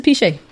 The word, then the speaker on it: Piche: P-I-C-H-E. Ain't no T. – Oh. – It's E. – Piche. – So it's Piche.